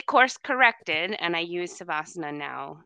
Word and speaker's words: Savasana: And I said, is that how you say it? Because I've heard people say Shavasana. And she says, oh, no, it's Savasana.